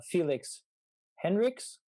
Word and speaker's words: Felix Henrichs: Yeah, so the easiest way probably would be to find me on Facebook under my name, Felix Henrichs. It's spelled, my last name is spelled H-E-N-R-I-C-H-S.